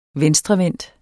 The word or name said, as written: venstrevendt